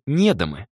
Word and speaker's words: недомы: Их называли недомы, с ударением на «е», сокращенно от недомаги.